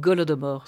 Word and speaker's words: Holodomor: Vous me corrigez, Holodomor, Golodomor ? – Golodomor. – Ce qui veut dire ? Ça s'écrit avec un H.